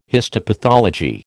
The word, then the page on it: histopathology: American English